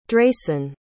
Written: dreyson

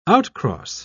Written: outcross

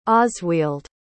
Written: osweald

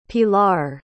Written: pilar

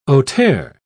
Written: auteur

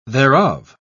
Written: thereof

